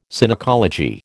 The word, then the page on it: Synecology: American English